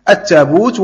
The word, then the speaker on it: التابوت: لهجات عربية كالتابوت والتابوه، التابوت والتابوه، بعض القبائل العربية كانت تنطق بالتابوت وهي يعني طبعا لغة قريش، وبعضهم كان ينطق بالتابوه، فيعني وكلاهما من الأحرف السبعة، لكن عثمان طبعا يحاول أن يجمع الناس على يعني لغة واحدة.